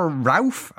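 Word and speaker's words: Rauff: Now, Walter Rauf, I don't know if I'm pronouncing his surname correctly. You are indeed.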